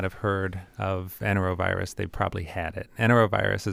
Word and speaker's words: Enterovirus: Let's move on to enterovirus 68. Mm-hmm. Am I pronouncing that correctly? Enterovirus 68. – Yes.